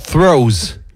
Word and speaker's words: Threos: j'espère que je vais le prononcer correctement, puisque c'est Forerunning the Toes, c'est bon ? Non ? – Il saura dire T-H-R-E-O-S. – T-H-R-E-O-S. The Throws.